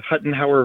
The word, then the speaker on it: Huttenhower: The Huttenhower version is right for the last name, and it's a delight to join you.